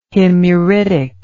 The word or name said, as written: himyaritic